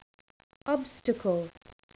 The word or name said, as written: obstacle